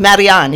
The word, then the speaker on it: Marianne: His name was, I kept saying Marion. And she was like, no, it's Marianne. You're saying it wrong.